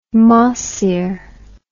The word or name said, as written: mahseer